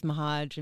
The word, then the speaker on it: Meharg: And I'm just going to go by the name Sarah Sabotage because A, it rhymes with Meharg, and B, it's like true to form to how I used to live my life.